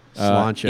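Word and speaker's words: Sláinte: S-L-A-I-N-T-E. – Slaint Math? – Slauncha. – M-H-A-T-H.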